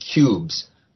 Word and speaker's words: Qubes: And I hunted around, which is harder to hack generally, but it wasn't a month ago, and there is a Linux distribution called Cubes, but Cubes spelled with a Q, Q-U-B-E-S, which markets itself, I shouldn't say markets itself because it's free, completely free, which presents itself as a reasonably secure operating system.